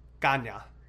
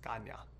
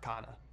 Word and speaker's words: Canha: So it's pronounced Canha because it's Portuguese descent. And he's like, yeah. – Interesting. – Yeah, he's like, his ancestry goes back to Portugal. And he's like, yeah, it kind of just got lost over generations, but like, I kind of learned over the years, like, it's actually pronounced Canha, not Canha.